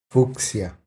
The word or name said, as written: Fucsia